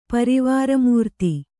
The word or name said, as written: ಪರಿವಾರಮೂರ್ತಿ